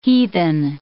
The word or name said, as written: heathen